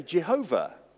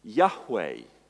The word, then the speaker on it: Yahweh: For many years, people thought that you should put these consonants together and say Jehovah. But in the last 50 years plus, scholars have concluded it's more likely pronounced Yahweh.